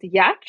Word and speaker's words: Giertz: So my last name, G-I-E-R-T-Z, is inexplicably pronounced Yetch.